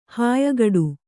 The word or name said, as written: ಹಾಯ್ಗಡ